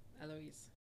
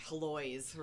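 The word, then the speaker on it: Héloïse: How do you say the blonde lady's name? – Eloise. – Eloise. Okay. All right. Thank you for saving me from having to say, like, Heloise or something.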